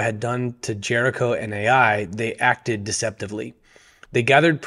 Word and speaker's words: Ai: And my interpretation, is based on this right here. When all the kings heard about Jericho and Ai, or I, as I hear some people pronounce it, that's when the idea was formed.